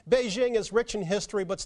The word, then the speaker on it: Beijing: That correct pronunciation is Beijing. – I think Bryant needs a little bit of a lesson in Chinese, and since John's a Chinese language teacher, I think he should explain how the word Beijing should be pronounced. – And I think it's quite simple to get it pretty close, and I feel like the Jing, as we say, is in jingle bells, and we also offer jingoism for those who like variation, is pretty close.